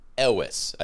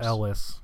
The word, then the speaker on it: Elwes: Elwes, I think. – Is how it's pronounced. – Elwes.